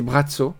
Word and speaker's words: Braco: ça s'écrit Braco. Et on dit Brazzo.